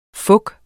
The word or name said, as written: fok